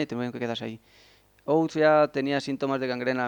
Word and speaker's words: Oates: El siguiente fue el capitán Oates, creo que se pronuncia así. Es Oates, Oates.